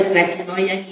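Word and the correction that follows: Tlachinollan: y a la, nunca lo puedo pronunciar bien, Tlachinolan, ¿verdad? Así es, Tlachinolan, y a toda la organización por su lucha constante y el apoyo que le han dado a Valentina y a otras.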